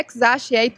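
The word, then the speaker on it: X Æ A-12: A pronúncia, Paulo Leite, é ex ache Ah, não.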